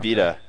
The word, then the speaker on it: Vita: That's the way they've been pronouncing it, at least. – Yeah. – Vita, as in life.